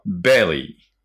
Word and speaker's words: belly: この場合お腹は belly, belly。 B で L ですからね、発音がね。